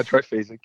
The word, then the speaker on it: triphasic: And your book, the triphasic, is that how you pronounce it? – Yeah, triphasic. Yeah.